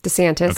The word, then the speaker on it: DeSantis: So Ron DeSantis, I don't care if I'm pronouncing his name correctly. – DeSantis. – Okay, fine. Ron DeSantis, he won by 32,463 votes, popular votes.